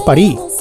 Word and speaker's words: París: Porque los franceses de París no pronuncian la S cuando dicen París, ¿no? Os lo digo por si vais allí y tal y queréis parecer un poquito de allí, no pronunciéis la S.